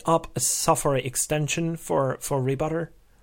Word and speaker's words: Rbutr: Also, to make sure, it's spelled R-B-U-T-R, so Rebutter. – Rebutter, yeah.